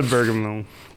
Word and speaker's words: Obergefell: Or maybe we should start celebrating when a Bergamot is passed. – Obergefell? – No. Mike, this is embarrassing for you. You pronounced it so wrong. It's a Obergefell.